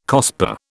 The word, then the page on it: COSPAR: British English